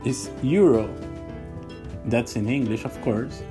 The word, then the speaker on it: euro: So we have again two vowels together, E and U, and it's spelled E-U-R-O as well. So we have to pronounce it like E-U, euro, euro. So it's not euro, it's not euro like in German, it's euro, E-U.